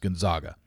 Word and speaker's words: Gonzaga: It's Zags on their jersey, but it's pronounced Gonzaga.